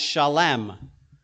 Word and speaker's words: Salem: Salem was a small kingdom city in the area where Jerusalem is now. In fact, Jerusalem was built on Salem. Jerusalem, okay? In Hebrew, that word Salem is pronounced Shalem, and it's very close.